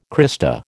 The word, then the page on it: crista: American English